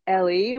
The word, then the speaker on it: Ellie: So if I can just spell it real quick, yeah, it's at Eli, which is E-L-L-I-E, and then Kohanim is C-O-H-A-N-I-M. It's all one word, at Eli Kohanim.